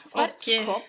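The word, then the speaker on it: Schwarzkopf: Ja, det är tillsammans med Svartskopp som jag har gjort lite grejer tillsammans med. – Svartskopp? – Ja. – Ja, förlåt. Här, jag ville bara uttala det på tyska.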